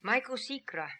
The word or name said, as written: Maikosikra